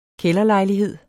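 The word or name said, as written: KÆLDERLEJLIGHED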